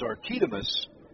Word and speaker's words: Arquimedes: First name is Arquimedes, which you would think is Archimedes, and that's easy to say for some reason. Pitch way outside for a ball, but nope, he puts the accent on the second syllable, so it is Archidamus Gamboa.